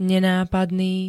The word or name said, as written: nenápadný